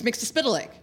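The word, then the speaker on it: Mxyzptlk: Paul Dini says it's Mixlispitalek. – Mixlispitalek.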